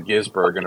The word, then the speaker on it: Gisbergen: I kept waiting for them to do Stevie Ray Vaughan, but we got Shane Van Gisbergen or SRV. The Giz was often soft, and it was the Gisbergen. The B was the hard pronounced.